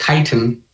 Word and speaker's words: chitin: So a friend of mine, and I'm sure you've It's actually pronounced chitin. That's funny.